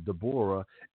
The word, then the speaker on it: Deborah: And immediately I thought about Deborah, or some would pronounce it Deborah, and Barak, and Barak, not the president, but he was a judge in the book of Judges, the fourth chapter.